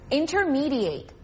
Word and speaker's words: intermediate: Intermediate. 이번에는 동사로 쓰일 때의 예문. 달라지는 발음에 유의해서 들어보세요.